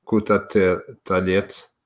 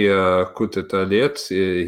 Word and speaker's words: Kutateladze: So maybe, and I think the pronunciation is Kutateladze.